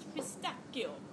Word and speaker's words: pistachio: The gentleman tells me it is pronounced pistachio.